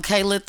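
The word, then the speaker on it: Kalit: I'm Kaylit the motherfucking rapper.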